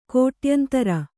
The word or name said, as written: ಕೋಟಾಯಂತ್ರ